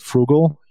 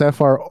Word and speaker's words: Froogle: You're being careful with your money, but it's Froogle. – Oh, I see. – Like spelled like Google.